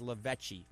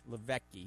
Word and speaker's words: Lavecchi: Uh, L I V E C H I just the way it sounds, Lavecchi, Lavecchi. He's Italian.